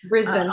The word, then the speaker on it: Brisbane: Brisbane.